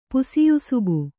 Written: ಪುಸಿಯುಸುಬು

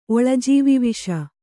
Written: ಒಳಜೀವಿವಿಷ

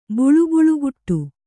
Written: ಬುಳುಬುಳುಗುಟ್ಟು

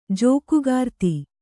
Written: ಜೋಕುಗಾರ್ತಿ

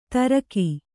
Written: ತುರಕ

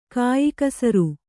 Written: ಕೆಯ್ಕುಸುರಿ

